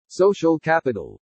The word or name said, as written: SOCIAL CAPITAL